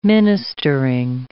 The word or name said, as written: ministering